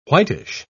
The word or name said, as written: whitish